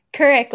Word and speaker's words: Kuryk: Kuryk, like Katie Kuryk.